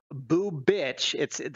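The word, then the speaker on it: Bubic: Kikuchi, Watkins, Otto, Bubich. That's how you say it, actually.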